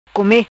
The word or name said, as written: Come